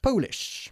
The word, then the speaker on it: Polish: Sería, John speaks many languages, he even speaks Polish. Not to be confused with Polish, que es el brillo para los zapatos.